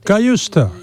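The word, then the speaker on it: Cajuste: Jens Cayuste, el E para la A. Jens Cayuste, que no es Cayuste, ni Cayuste, ni Cayuste. Jens Cayuste.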